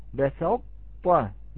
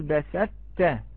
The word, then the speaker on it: بسطت: فرطتم، لا نقول فرطتم بسطت بهذه الطريقة، ولا نقول بسدت، لا نطق إدغاما تاما، لا نقول بسدت.